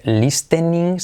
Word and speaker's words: listening: La T no se pronuncia. No se dice listening, sino listening. Listening.